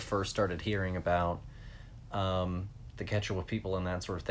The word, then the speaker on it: Quechua: It's pronounced Quechua.